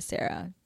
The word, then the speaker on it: Sara: No, you can just tell them like if someone I'm like the correct pronunciation of my name is Sara.